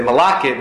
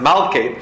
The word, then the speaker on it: Malkit: Okay, I know you want to probably say Malakit, it's actually pronounced Malkit or Rehitni.